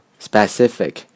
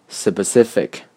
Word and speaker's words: specific: 和大多数学生一样，我也曾经把 specific 读成 specific，而不是 specific。